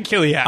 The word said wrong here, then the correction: Kalik: And so this guy's like, hey, man, it's pronounced Kalik, yeah. Not, you know.